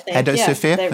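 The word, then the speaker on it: Hedosophia: The fundraise has also been backed by, going to definitely mispronounce this, Hedrosophia. Not sure if I got that right. There's a few people nodding, probably got that right. I'm going to move on. – Head of Sophia. – There we go.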